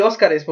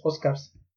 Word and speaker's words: Oscars: O sea, literalmente les digo Oscars. Pero es que ni siquiera se podría decir Óscares, porque ni siquiera lleva eh, R-E-S, sino es Oscars. O sea, en español y en inglés se pronunciaría igual, ¿no? Porque sería Oscars.